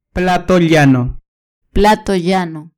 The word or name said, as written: plato llano